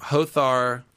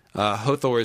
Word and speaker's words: Hother: So Emma Smith, Storm Emma, as I like to call her, Archmaester Emma, she points out that Hothor, Horsbane, has some great wordplay. So Hothor is spelled Hother. So it's basically the word other with an H in front.